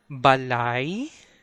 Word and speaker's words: balay: For example, in the word balay, balay, where it ends with letter Y, the consonant Y is the trigger to switch from A sound to E sound in the last syllable, lay. That's ba and lying. So how do you spell that word? That's B-A-L-A-Y. Ba-lay. Ba-lay. Ba-lay. So lie actually has a diphthong at the end.